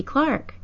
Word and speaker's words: Clark: Hi, my name is Stacy Clark. Stacy rhymes with Casey, Lacey, Macy, and Racy. Clark rhymes with bark, dark, hark, mark, park, and stark.